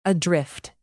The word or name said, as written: adrift